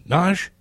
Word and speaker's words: Nagy: Phyllis Nagy. – Nagy? Oh, is it true? There are so many ways to mispronounce that name.